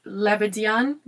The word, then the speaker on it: Lebedyan: Another company um, so I looked up the pronunciation of this, but don't at me. Um, so I think it's Le- Lebedyan, Russia.